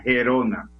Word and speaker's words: Gerona: Ayer perdió un partido frente al equipo sorpresa de la Liga Española, el, Girona Girona, Girona, Gerona, vi que se, que se debe pronunciar según la Real Academia, Gerona.